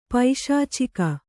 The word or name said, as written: ಪೈಶಾಚಿಕ